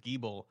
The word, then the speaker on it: Giebel: Because it was an inside joke for me because no one's ever pronounced my name right. they would say Giebel instead of Giebel.